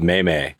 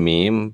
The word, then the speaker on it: meme: but he pronounced it may-may. And people were just like, what did he do? What did he do? On like a video interview? He's like, does he mean meme?